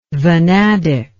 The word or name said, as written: vanadic